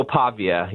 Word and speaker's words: Pavia: And tell me about your concerns going into Vanderbilt to take on, is it Diego Pava? Is that how you say his name? – Diego Pavia, yeah. He's a Pavia.